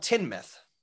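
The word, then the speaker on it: Teignmouth: and then there's a town at the mouth of the river. – Yeah. – And it's called Tinmouth. – Not Teen Myth.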